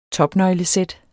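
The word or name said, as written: topnøglesæt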